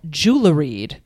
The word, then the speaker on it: geoluhread: The color orange was initially called jewelried, which is spelled... – That's beautiful. – I know, right? And it's even prettier. It's Old English. It's spelled G-E-O-L-U-H-R-E-A-D. Jewelried, which means yellow red.